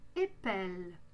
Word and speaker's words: épelle: On prononce un « e » et on double le « l ». On prononce donc « épelle »,« j'épelle »,« tu épelles »,« il »,« elle »,« on » épelle »,« il »,« elles » épelle ».